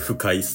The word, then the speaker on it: 腐海: あの、腐る海と書いて腐海っすね。 – ああ、はいはいはい。 – 腐海といえば、毒。ということで、これを提案したい。そのもの、金色の鎧をまとい、腐海に降り立つべし。